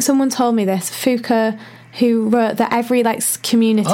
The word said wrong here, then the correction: Foucault: We ought to spell out Foucault. It's Foucault. – Yeah. Wait, how are you... Am I saying it right then? – Yeah, Foucault. It's French.